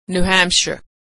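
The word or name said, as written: New Hampshire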